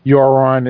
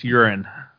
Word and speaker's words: Euron: It could be something like Jaime is forced to send a troop there, or Euron is there, or someone's going to get there. – You said that wrong. It's Euron.